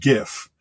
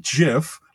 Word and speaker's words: GIF: You know, it's pronounced GIF. It's graphic with a hard G. It's not GIF, like as if it's giraffic.